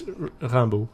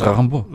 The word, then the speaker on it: Rimbaud: Did I mispronounce his name? – Well, no. – Go on. Can you correct me all the time? – Well, no, but I mean, it's Rimbaud.